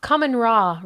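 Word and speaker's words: Kahmunrah: I'm going to mispronounce it. It's called Night at the Museum. Kumunara Rises Again. Kamun Ra Rises Again. There we go.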